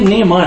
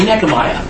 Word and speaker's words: Nehemiah: how do we get Nehemiah? I mean, that's the way it reads, but it's actually Nehemiah.